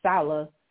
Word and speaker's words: Saleh: So I don't know if I can necessarily blame Robert Saleh, did I say that right, on this. Because you got that right.